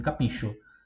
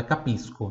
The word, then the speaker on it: capisco: Eu já vi pessoas respondendo assim, capicho. Mas não, porque como tem o O, não tem som de X. Então é capisco, tá? Capisco. Capisco significa entendo. Capisco. Se escreve C-A-P-I-S-C-O. S-C-O.